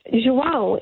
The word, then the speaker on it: João: J-O-A-N, because João in Portuguese is spelt J-O-A-O with a tilde above the A.